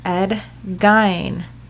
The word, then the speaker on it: Ed Gein: You know, if I was like the real true crime people, now this is true, guys, it's actually pronounced Ed Gein.